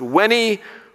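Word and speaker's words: Veni: Mrs. Pichotti taught us to pronounce the V's as W, and so she taught us to pronounce this Wenny, Weedy, Wicky, which translated, Julius Caesar says, I came, I saw, I conquered.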